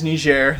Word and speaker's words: Niger: but no, it is Niger.